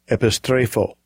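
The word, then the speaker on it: epistrepho (ἐπιστρέφω): this is this is how you pronounce it. Strong's G 1994 epistrepho. Epistrepho epistrepho.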